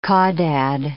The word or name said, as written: caudad